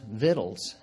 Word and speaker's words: victuals: Send the multitude away that they may go into the villages and buy themselves victuals.'" And that's how it is pronounced, victuals, not victuals, victuals.